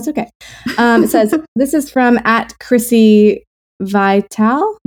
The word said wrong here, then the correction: Vitale: How would you pronounce that? I think that's right. – Okay. Vitale. – Chrissy Vitale. – I don't know. I'm joking. It's okay. V-I-T-A-L-E. Vitale. Chrissy Vitale. Wow, Chrissy, we are so sorry.